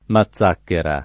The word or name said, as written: mazzacchera